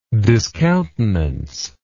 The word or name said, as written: discountenancer